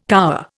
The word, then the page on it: cower: Indian